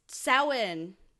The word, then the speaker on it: Samhain: I'm Samhain. – Okay. – That's how you pronounce it.